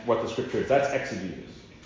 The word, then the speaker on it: exegesis: Exegesis is, if you want to spell that, E-X-E-G-E-S-I-S.